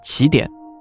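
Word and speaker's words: 奇点: 所以，这里的“ 奇” 的词义呢，是特殊的，而不是非双数的奇偶的那个“ 奇”。所以，大多数科普同行都会把这个词念成起点。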